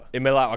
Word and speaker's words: Iamaleava: Nico Iamaleava. I think I said that right? – Iamaleava.